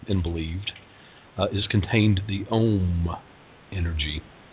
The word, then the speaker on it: Om: And Om is an interesting word, whereas it's a simple syllable, O-M, it has three distinct vibrations, O-M, O-M, A-O-M. It starts in the nasal cavity and ends in the chest cavity, O-M.